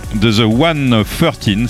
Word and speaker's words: The 113: Il s'agit de The 113, c'est comme ça qu'il faut le prononcer visiblement.